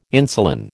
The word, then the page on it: insulin: American English